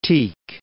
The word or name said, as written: Tieck